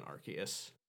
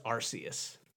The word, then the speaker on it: Arceus: it seems like maybe the canonical pronunciation of it has been Arceus, and well, it's still dumb and wrong. – Yeah, it's bad. No one likes that hard k sound.